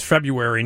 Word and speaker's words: February: It says February, not February. Somebody forgets the R after the B sometimes, but it is February. But some people pronounce it February, which is wrong.